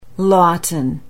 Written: Lawton

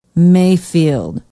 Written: mayfield

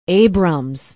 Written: abrams

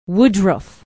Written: woodruff

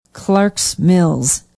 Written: Clarks Mills

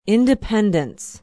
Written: Independence

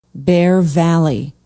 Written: bear valley

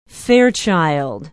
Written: Fairchild

